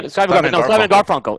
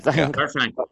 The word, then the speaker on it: Garfunkel: Simon Garfunkel. Yes. – Garfunkel.